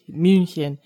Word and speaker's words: München: H sound is the CH, and the U has the umlaut over it, so it's Mün, not Moon. – München. Cool. – Granted, my German teacher was also from East Tennessee, so he may or may not have taught me the pronunciation correctly.